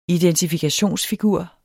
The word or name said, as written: identifikationsfigur